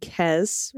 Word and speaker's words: Kez: As pod pet tax, I give you my 8-year-old Westie named Kez, rhymes with mess, named after a character from Star Trek Voyager because they have the same ears.